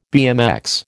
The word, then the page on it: BMX: American English